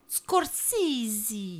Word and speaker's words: Scorsese: Parce que Ego, la dernière fois, nous a repris parce qu'on dit Scorsese.